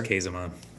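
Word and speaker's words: Käsemann: Is it Kazeman, the New Testament scholar, German scholar? – Kazeman. – Kazeman. Okay.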